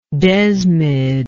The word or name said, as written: desmidian